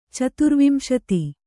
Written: ಚತುರ್ವಿಂಶತಿ

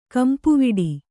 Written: ಗುಂಪುವಡೆ

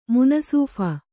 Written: ಮುನಸೀಫ

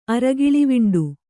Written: ಅರಗಿಳಿವಿಂಡು

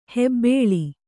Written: ಹೆಬ್ಬಳ

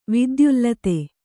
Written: ವಿದ್ಯುಲ್ಲತೆ

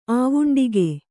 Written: ಆವುಂಡಿಗೆ

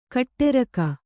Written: ಕೊಠಾರಿಕೆ

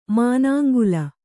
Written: ಮನಂಗಲ್

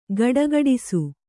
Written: ಘುಡುಘುಡಿಸು

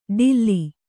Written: ಡಿಲ್ಲಿ

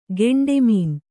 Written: ಕಂಟಮೊನೆ

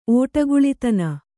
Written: ಓಡುಕುಳಿತನ

